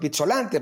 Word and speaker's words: Pizzolante: ¿En qué le puedo ser útil, señor Pizzolante? Porque además él, pronunciaba mi apellido como se debe pronunciar, con, doble Z.